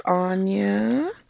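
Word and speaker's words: Ahnya: Simple, 5 letters, 2 syllables, real easy. That's A-H-N-Y-A. There's no O's in this, and there are 5 letters. Anya, let's talk about me.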